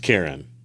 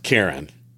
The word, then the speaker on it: Charon: It is pronounced Karen. – No, it's not. – The ferryman is pronounced Karen.